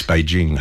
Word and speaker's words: Beijing: And Brian Henderson used to say to me, it's Beijing, not Beijing. It's Beijing, hard J, and it's Los Angeles, not Los Angeles.